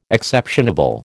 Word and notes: exceptionable: American English

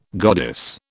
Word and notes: goddess: British English

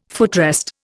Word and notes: footrest: Indian

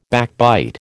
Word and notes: backbite: American English

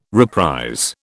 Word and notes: reprise: British English

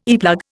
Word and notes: earplug: Indian

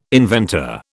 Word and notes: inventor: British English

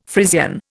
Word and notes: Frisian: Indian

